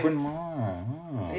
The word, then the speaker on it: Bryn Mawr: Oh, Bryn Mawr.